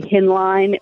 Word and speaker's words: Hinline: How do you pronounce your last name, Liz, if I may ask? – Joe, I am so proud of you. You're like literally the first person off the bat that can say Hinline without like stuttering.